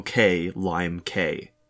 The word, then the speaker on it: Klimek: I think it's either Daniel Klimek, or it's, as you pointed out, Daniel K. Lime K. – Yeah, I think it's more like a Klimek.